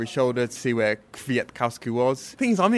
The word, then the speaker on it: Kwiatkowski: He has given us, he's broken it down into four syllables. Kwiatkowski. I can do all four syllables. – That's three syllables. – Michał Kwiatkowski. – No, Kwiatkowski. – It's not hard. Kwiatkowski.